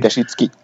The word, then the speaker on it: Gesicki: mondjuk így a Penn State kiválósága Mike eh, gezi ki, vagy, vagy Giziki, vagy a fene se tudja, hogy hogy ejtik. Gesicki.